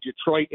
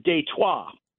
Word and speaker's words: Detroit: and the real proper pronunciation, and I know what a stickler you are for pronunciation, is Detroit.